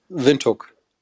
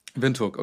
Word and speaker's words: Windhoek: Windhoek. – Windhoek, okay.